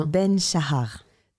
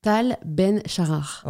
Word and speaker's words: Tal Ben Shahar: Tal Ben Shahar. – Tal Ben Shahar.